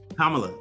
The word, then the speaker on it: Kamala: Um, Miss Harris, uh, Kim, Kamala. Kamala. There you go. I can I can never say her name right. Kamala Harris. Thank you. So she. Comma. Comma. La. La. That's it, bro.